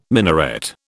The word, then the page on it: minaret: British English